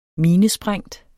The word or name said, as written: minesprængt